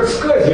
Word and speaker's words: SCSI: Почему-то произносится как SCSI, я не знаю почему, но везде в книжках пишут, что надо именно так и произносить.